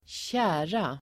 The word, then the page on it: tjära: [²tj'ä:ra]